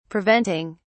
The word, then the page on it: preventing: British English